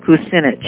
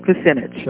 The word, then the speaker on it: Kucinich: Well, it's Kucinach, probably .com or .org. It rhymes with spinach, Kucinich. – All right, fine. – We know spinach is good for us, so check out Kucinich.